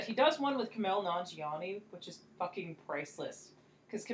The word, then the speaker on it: Nanjiani: No, Nanjiani. – Okay. – Because it rhymes with Poonani.